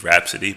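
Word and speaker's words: Rapsody: I'm pretty sure it's Rhapsody, but whatever. – What, Rhapsody? What did I say? Yeah. Rhapsody? Yeah. That's because of old habits. Die Hard. I've said Rhapsody before.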